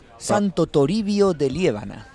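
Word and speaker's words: Santo Toribio de Liébana: It's a hard language. It's hard to get my English mouth around the pronunciation of Spanish. – Santo Toribio de Liébano. – Perfect. Well done. You got it right.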